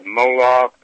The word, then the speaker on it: Moloch: Again, study about the god Moloch, it's spelled M-O-L-O-C-H in English, and you can do your search engine on that and come up with a lot of things that you'll see.